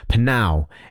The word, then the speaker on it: Pnau: Panau is how I say it. You know, P-N-A-U. How? What did I say? P-N-A-U. Not P-N-A-U.